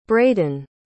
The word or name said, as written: braydon